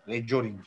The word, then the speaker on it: recurring: Rejoring diye okunuyor.